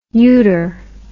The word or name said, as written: uter